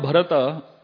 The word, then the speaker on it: Bharata: And so basically, Bharat, the word Bharata, it consists of three syllables, Bha, Ra, and Ta.